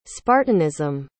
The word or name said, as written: spartanism